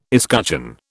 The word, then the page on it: escutcheon: British English